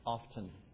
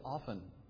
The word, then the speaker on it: often: The word O-F-T-E-N is not pronounced often. That's not the... According to this book, that's not the correct pronunciation. Of course, the book was a Bob Jones University textbook, so maybe that has something to do with it. Well, James isn't here to teasing. But according to the textbook, the correct way to say that is often, like O-F-F-E-N.